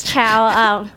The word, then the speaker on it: Cao: Yes, it is Chow. Oi has been. I didn't change it for my new job, but it's spelled C-A-O, which is the Mandarin spelling of the chow, C-H-O-W. So it could have been C-H-O-W, then it would have been...